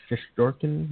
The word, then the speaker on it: Shestorkin: Help me out here. Sheshkarin? Sheshkorin? – Shestorkin, yeah, whatever.